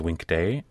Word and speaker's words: Winkte: Well, it's actually pronounced Winkte, and it's actually short for a longer word, Wi'inkte.